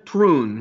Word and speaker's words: Thrun: Today we are going to feature Sebastian Troon. His last name is spelled T-H-R-U-N.